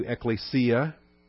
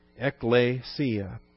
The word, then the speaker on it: ecclesia: I expect that by now you've gotten accustomed to ecclesia. You can read. It even looks like E-K-K-L, doesn't it? Ecclesia. Short E, long E.